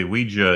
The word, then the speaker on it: Ouija: Actually, Ouija is the preferred pronunciation. – By who? No one. – On the dictionaries.